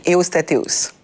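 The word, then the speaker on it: Ewostatewos: Takla Emano et Evostateos. – Tekla Aymanot et Eostateos.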